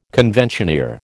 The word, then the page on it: conventioneer: American English